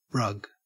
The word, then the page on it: rug: Australian English